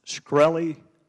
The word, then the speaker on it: Shkreli: Is it pronounced Shkreli? – Yes, sir.